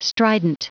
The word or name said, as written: strident